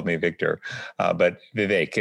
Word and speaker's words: Vivek: Uh, so I can tell you that my first name is pronounced Vivek. The VI is like the VI in Victor. The VEK rhymes with lake.